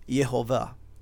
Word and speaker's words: Yehovah: to Yahweh, right, or Yehovah, depending on how you want to pronounce it.